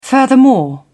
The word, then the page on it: furthermore: British English